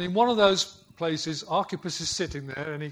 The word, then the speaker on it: Archippus: Archippus. Remember there that CH is pronounced K like lock, like a Scottish lock.